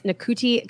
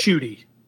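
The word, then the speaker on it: Ncuti: I think it's Nakuti Gatwa. I hope I'm saying it right. – Shooty.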